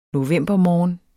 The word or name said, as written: novembermorgen